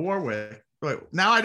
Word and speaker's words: Warwick: I love how you're correcting me on your incorrect No, but Warwick Davis was telling me how to pronounce there. He's like, it's Warwick, or it's Warwick, and they're like, Warwick. He's like, no, drop the W. They're like, Orwick. He's like, no, not the first W.